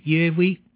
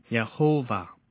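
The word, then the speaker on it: Yahweh: 一般认为神的名字的发音是耶威，但是随着时间的过去，不知什么时候发耶和华或者 Yehovah 这个音了，而且呢越来越普遍。但是可能正确的发音是耶威，只是我们不能确定。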